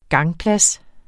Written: gangplads

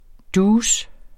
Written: douce

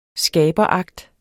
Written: skaberakt

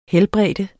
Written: helbrede

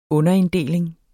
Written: underinddeling

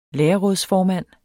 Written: lærerrådsformand

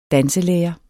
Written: danselærer

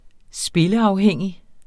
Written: spilleafhængig

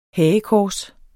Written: hagekors

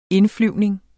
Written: indflyvning

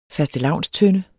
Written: fastelavnstønde

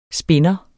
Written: SPINDERE

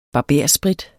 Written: BARBERSPRIT

